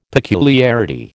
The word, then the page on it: peculiarity: American English